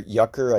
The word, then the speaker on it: Jucker: Swiss avant-garde folk singer Louis Jucker, or Jucker, I guess, if you want to pronounce it in the German way. They don't pronounce their J's like we do over here.